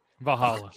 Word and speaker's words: Valhalla: Valhalla.